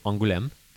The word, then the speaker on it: Angoulême: Say it properly. – Angoulême. – There you go.